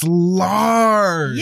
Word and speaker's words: Lars: It's German. Rhymes with cars. – It's Lars.